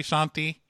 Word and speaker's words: Shanti: Like, hey, Auntie Shanti. You know, Auntie rhymes with Shanti.